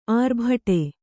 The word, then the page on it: ಆರ್ಭಟೆ: ārbhaṭe